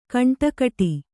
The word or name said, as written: ಖಂಡಗಟ್ಟು